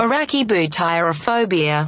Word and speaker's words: arachibutyrophobia: Arachibutyrophobia. – Okay, should we get the actual pronunciation? – Arachibutyrophobia. – Oh, tyrophobia. – I mean, you weren't far off.